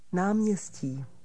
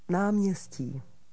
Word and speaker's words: náměstí: Václavské náměstí. Václavské náměstí. – Repeat the word for square. – Náměstí. Náměstí. – What's the Czech for square? – Náměstí. Náměstí.